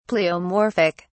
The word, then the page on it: pleomorphic: British English